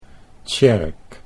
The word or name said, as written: Tsjerk